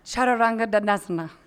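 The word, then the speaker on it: Chaturanga Dandasana: I know like Chaturanga Dadasana, and I don't. – Good pronunciation. One more time. – Chaturanga Dadasana. – It means four limb stick.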